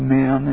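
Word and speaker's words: men (μέν): In verse 18 you have a little word called pronounced men, and 19 a little word pronounced day, and when you put those two together, it always translates on the one hand, but on the other hand.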